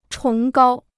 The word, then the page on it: 崇高: chóng gāo